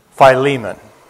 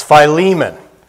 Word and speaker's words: Philemon: Of his 13 epistles, this is the shortest, namely his letter to Philemon. Years ago, as a brand new believer, I made reference to the book of Philemon. And an older believer corrected me and said, "It's Philemon."